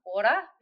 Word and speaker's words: Ora: So it's actually pronounced Aura because it's a Māori word. I'm a Kiwi in case you couldn't tell. So it's ORA Sleep Coaching.